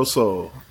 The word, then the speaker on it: Sol: It's by Cleo Soul. Cleo is spelled Cleo, like you would think, and Soul is spelled S-O-L.